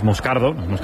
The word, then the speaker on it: Moscardo: Entonces, sigo, como bien ha pronunciado Unai, es Moscardo,